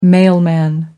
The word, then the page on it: mailman: American English